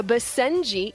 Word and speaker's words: Basenji: A Basenji, Ian, is that how I pronounce it? – That's right.